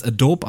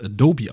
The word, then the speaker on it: Adobe: So, viele Leute sprechen es Adobe aus, das ist falsch. Es wird Adobe ausgesprochen, liebe Leute.